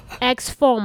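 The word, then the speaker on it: IXFORM: X form 吗？I 不发音。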